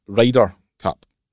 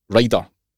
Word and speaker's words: Ryder: Forget the spelling. It's pronounced Ryder Cup. Ryder.